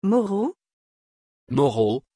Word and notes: Moro: French

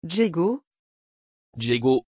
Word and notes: Djego: French

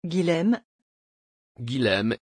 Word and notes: guillem: French

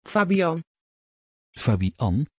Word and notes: fabian: Dutch

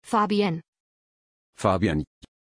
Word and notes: fabienn: German

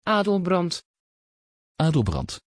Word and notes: adelbrand: Dutch

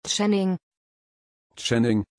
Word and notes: channing: German

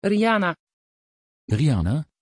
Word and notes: Riana: Dutch

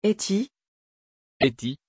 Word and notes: hettie: French

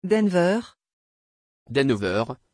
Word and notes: denver: French

